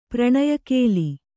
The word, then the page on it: ಪ್ರಣಯಕೇಲಿ: Kannada